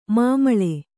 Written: ಮಮ್ಮುಳಿ

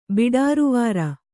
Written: ಬಿಡಾರುವಾರ

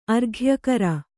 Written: ಆರೋಗ್ಯಕರ

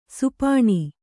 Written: ಶಪನ

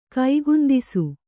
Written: ಕೈಗುಂದಿಸು

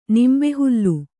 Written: ನಿಂಬೆಹುಲ್ಲು

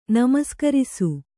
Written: ನಮಸ್ಕರಿಸು

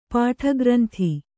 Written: ಪಾಠಗ್ರಂಥಿ

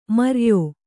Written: ಮೈರೇಯ

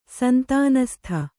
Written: ಸಂತಾನಸ್ಥ

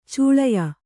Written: ಛಳೆಯ

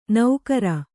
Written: ನೌಕರ

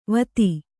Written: ವತಿ

